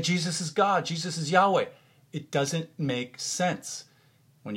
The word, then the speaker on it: Yahweh: That's the personal name of God, pronounced either Yahweh or Yehovah, Yehovah.